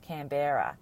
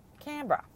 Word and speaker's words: Canberra: Firstly, the story was set in our nation's capital, which you pronounced Canberra. And down here, we call it Canberra.